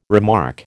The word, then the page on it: remark: American English